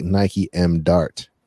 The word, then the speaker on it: NikeMd Art: Nike MD art. That's how you say it, right? Or is it like Nike M Dart? – No, yeah, Nike MD art.